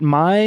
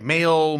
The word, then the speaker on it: Maille: Am I saying that right? Is it My? – Yes. Yeah. – Okay. – Mm-hmm. Yep. And I know most people are like male, my, yeah.